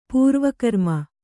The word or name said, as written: ಪೂರ್ವಕರ್ಮ